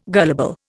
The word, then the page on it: gullible: Indian